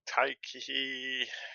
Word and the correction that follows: Taika Waititi: Taika Waititi. – Taika Waititi. Yeah, there you go.